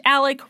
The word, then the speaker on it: Alex: Alex is pronounced Alec.